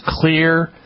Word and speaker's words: Xlear: Clear is spelled with an X, X-L-E-A-R. Clear, X-L-E-A-R, nasal sprays and the Spry Dental Defense products.